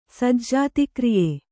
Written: ಸಜ್ಜಾತಿಕ್ರಿಯೆ

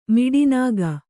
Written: ಮಿಡಿನಾಗ

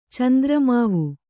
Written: ಚಂದ್ರಮಾವು

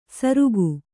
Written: ಸರುಕು

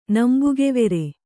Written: ನಂಬುಗೆವೆರೆ

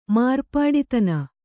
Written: ಮಾರ್ಪಾಡಿತನ

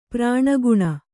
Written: ಪ್ರಾಣಗುಣ

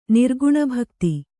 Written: ನಿರ್ಗುಣಭಕ್ತಿ

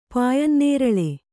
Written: ಪಾಯನ್ನೇರಳೆ

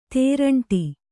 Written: ತೇರಂಟಿ

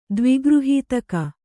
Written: ದ್ವಿಗೃಹೀತಕ

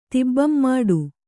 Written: ತಿಬ್ಬಂಮಾಡು

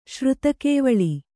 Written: ಶ್ರುತಕೇವಳಿ

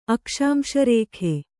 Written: ಅಕ್ಷಾಂಶರೇಖೆ

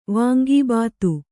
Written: ವಾಂಗಿಭಾತು

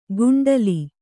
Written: ಕುಂಟಲಿ

